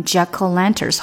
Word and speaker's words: jack-o'-lanterns: 大家看到这个字的写法是中间有一个 o，但这个 o 呢，它其实发了一个的音，所以你把它连读起来就变成 jack-o'-lanterns，好像 o 没有发音一样。